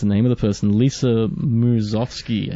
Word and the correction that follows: Lisa Miskovsky: I think. She sings the theme song, and this is a remix. – Lisa Miskovsky. – Miskovsky.